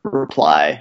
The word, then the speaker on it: Reply: Yeah, probably the main one is Reply, which is completely like non-creative name, but yeah, it's a REPL and it's spelled like the word reply because I thought it'd be funny to have something that everyone pronounced wrong. Yeah, I pronounced it Reply and I thought it'd be kind of fun to name a library with a stupid name that people would pronounce a different way than I did.